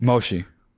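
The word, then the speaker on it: Moshi (もしもし): Just mushi, dude. – Moshi. – Moshi? – Moshi. – How do you know it's moshi? – So you spell it M-O. – Moshi, moshi? – Moshi, moshi. – I like mushi. – Well, sometimes it sounds like that because I say it fast, you know? – Yeah.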